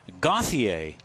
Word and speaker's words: Gauthier: Austin Gauthier. I'm giving him the French pronunciation.